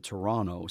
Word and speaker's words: Toronto: The locals will call it Toronto. So you'll always know when someone's not from here by their overpronunciation of the second T or mm-hmm. their actual pronunciation, their correct pronunciation, I should say, of the second letter T in the name, right? – Yeah, I would totally say Toronto.